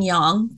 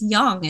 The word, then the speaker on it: yang: And by the way, thank you for saying yang. Thank you for pronouncing it correctly. Maybe a lot of people don't know that it's yang. It's yin and yang.